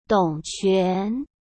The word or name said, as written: Dong Quan